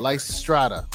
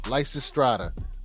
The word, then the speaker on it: Lysistrata: Lysistrata. Lysistrata.